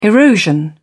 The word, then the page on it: erosion: British English